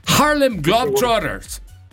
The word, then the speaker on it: Harlem Globetrotters: Para Harley Dog Brothers. Para Harley. Los Harley, ¿no? – Pero a ver, ¿cómo se dice? – Los Drop Brothers. – Te tienes que meter a Harmon Hall, ¿eh? Harlem Globetrotters.